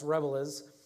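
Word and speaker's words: Rabelais: It's like Rebelez, or it looks like Rebel, but it's not. It's Rebelez, who also was a Franciscan friar.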